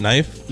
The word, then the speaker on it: Nif: Is that how you pronounce it? Knife? – Yeah. – It's spelled N-I-F.